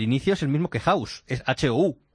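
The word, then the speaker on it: Houzz: House.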